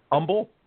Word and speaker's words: Humble: He's in Humble ISD in Texas, but I understand, if I'm correct, Dustin, it's pronounced Humble? – That's correct. We dropped the H for some reason.